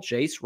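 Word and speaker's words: Jase: Jace rhymes with case, spelled like that too.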